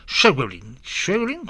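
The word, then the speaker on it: Schweblin: Opiniones dicen que sería la argentina Samantha ¿cómo se pronuncia esto? Sherwin, ¿cómo se llama? Ay, estoy metido. Esto es como Schuster, como Schuster, entonces Sherwin, ya.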